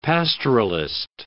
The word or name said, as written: pastoralist